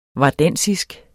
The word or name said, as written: vardensisk